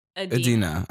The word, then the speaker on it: Idina: Is it not Idina? – It's just Idina. Okay. – Idina. Not I.